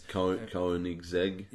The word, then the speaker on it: Koenigsegg: the Kinesloggy or whatever it is. – Koenigsegg.